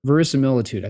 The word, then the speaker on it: verisimilitude: Verisimilitude. I think I'm pronouncing that correctly. Yeah, yeah, that's right.